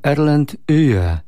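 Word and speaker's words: Erland Øye: Taki list do radia, dzięki za Erlanda, ale to się wymawia twardo. Erland Uje.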